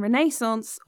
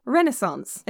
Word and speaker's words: Renaissance: And I must say, it's pronounced American Renaissance or Renaissance, as you Americans do.